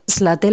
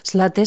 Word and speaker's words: Zlateh: Slaté la cabra y otras historias. Slaté se escribe Zlateh.